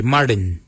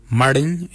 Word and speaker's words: Marrdiny: Kujo is spelled G-O-O-D-J-A-L. Kujo. For 3, we say Martin. Martin is 3. Martin is spelled M-A-R-R-D-I-N-Y.